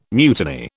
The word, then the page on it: mutiny: British English